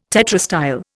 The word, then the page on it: tetrastyle: Indian